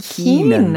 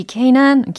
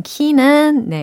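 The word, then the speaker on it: K'naan: Keenan. Keenan. – Uh, he it, that means yeah. traveler. in the Somalian language. language 아하, yeah. 그래요. 이 케이난, 키는 네라고 발음을 해야 될 것 같은데 어, 소말리아의 의미로 traveler 여행자라는 의미래요.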